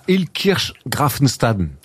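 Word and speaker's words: Illkirch-Graffenstaden: Ouais, il joue à Ilkirch-Grafenstaden.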